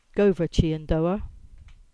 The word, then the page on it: Chyandour Brook: Cornish